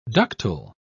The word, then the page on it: ductule: British English